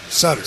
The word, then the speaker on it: Sutter: Doug Evans, Cliff Ronning, or Brian Suter? – Sutter. – Sutter. Damn, I said it right the first time. – Is it Sutter? Sutter. – Yeah, it's Sutter.